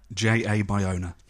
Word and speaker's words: Bayona: J.A. Bayona.